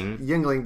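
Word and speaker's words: Yuengling: Yes, it's still pronounced Yingling, but they changed the spelling.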